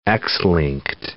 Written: X-linked

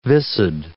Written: viscid

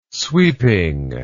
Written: sweeping

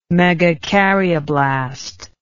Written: megakaryoblast

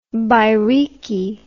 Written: bairiki

